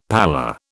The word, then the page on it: pallor: British English